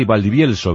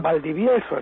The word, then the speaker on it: Valdivielso: Porque se dice así, Valdivielso, ¿verdad? – Valdivielso, efectivamente.